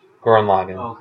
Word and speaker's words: Gurren Lagann: Gurren Lagann.